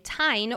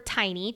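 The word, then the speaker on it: Teine: However, Mira is not come off from her dragon, Tyne, or Tiny, depending on which platform you're listening on. We're going to go with Tyne since that's how Rebecca pronounces it.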